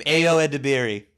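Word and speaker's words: Ayo Edebiri: A.O. Edebiri.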